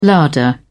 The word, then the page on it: larder: British English